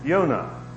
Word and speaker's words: Yonah: The key character, his name, of course, is Jonah, or pronounced in the Hebrew, Yonah. The English, to my knowledge, are the only ones who pronounce the letter J with the sound that they do. So in most of the rest of the languages of the world, Yonah would be pronounced.